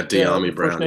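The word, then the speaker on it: Dyami Brown: Deami Brown, is that, am I pronouncing that right? – Yeah, Deami Brown, yeah.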